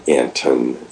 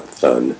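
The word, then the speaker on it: Anthon: But we did a study jointly analyzing what's been commonly known as the Anton transcript, which, oh, and by the way, it is pronounced with a hard T, the Anton, not Thun.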